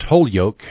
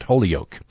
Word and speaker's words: Holyoke: Today's show will be like being home in South Hadley, Massachusetts, as the professor will explain to you the proper pronunciation of the college's name is an unbroken egg, not a sacred tree. Mount Holyoke, not Mount Holyoke.